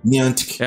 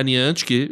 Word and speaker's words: Niantic: Como é que é? Niantic. É a Niantic, exato. Pega essa pronúncia.